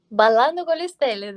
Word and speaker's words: Ballando con le Stelle: How did you come about being in, I'm sorry if I butchered this, Ballando con le Stelle? Did I say it correctly? – Ballando con le Stelle, that's right.